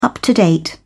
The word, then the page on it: up-to-date: British English